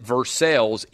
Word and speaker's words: Versailles: There's a town outside of Lexington, Kentucky called Versailles. They pronounce it Versailles, even though the town were named after Versailles.